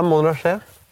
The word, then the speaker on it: montasje: Nå går du i montasje. Vandrer rundt. – Du har riktig uttale. Det er montasje må det skje.